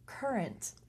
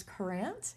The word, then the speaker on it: Courant: And then the Hartford Courant. – Yes. – Which we've been pronouncing as Courant.